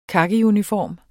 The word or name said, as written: kakiuniform